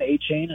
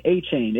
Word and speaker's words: Achane: Yeah, so I mean, I always thought going back to his college days at Texas a and M, I would always hear Devon Achane, and I'm pretty sure that's how the Texas A&M uh, pronunciation guide or their media relations staff had uh, provided, uh, whether it's uh, game broadcasters, announcers, commentators. I feel like I remember everyone saying A chain, A chain.